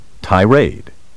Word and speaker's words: tirade: Tirade may also be pronounced with the accent on the second syllable, tirade. Word 8. Recur. R-E-C-U-R.